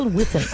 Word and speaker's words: Wiffen: Daniel Whiffin. – Could you spell Whiffin? – Rory, can you spell it? Can you spell it? – W-I-F-F-E-N.